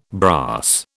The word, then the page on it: brass: British English